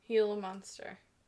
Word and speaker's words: Gila monster: The golden eagle was named the state bird of prey last year, and the Gila monster was designated the official state reptile in 2019. – Gila monster. – Right? That's how you pronounce that. – It is. Okay.